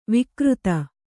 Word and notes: ವಕ್ರಿತ: vakrita; Kannada

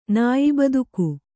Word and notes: ನಾಯ್ಬದುಕು: Kannada

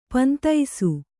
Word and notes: ಪೊಂದಿಸು: pondisu

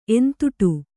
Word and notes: ಎಂತುಟು: entuṭu

